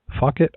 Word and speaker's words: Phuket: It is P-H-U-K-E-T, fuck it, underwater activist, dive off sea walking business for now. I don't think there's any other way to say that. – Puket? – Or P-Huck it?